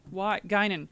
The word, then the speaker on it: Guinan: You're just looking at like, how do you pronounce that? Guinan. Yeah. – Because you just don't see it spelled.